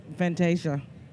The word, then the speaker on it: Vantaysia: My name is Vantaysia and it's spelled B A N T A Y S I A.